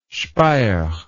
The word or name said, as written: Speyer